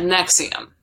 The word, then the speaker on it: NXIVM: It's actually spelled like N-X-I-V-M, but it's said what? Nexium.